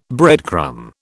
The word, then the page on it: breadcrumb: British English